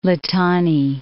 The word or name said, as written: Litani